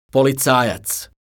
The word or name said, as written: policajac